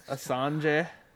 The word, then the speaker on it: Assange: And in news that probably everyone saw, the leader of WikiLeaks, Julian, how do you say his last name? Do you know? Assange.